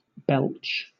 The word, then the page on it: belch: British English